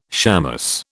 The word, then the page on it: shamus: British English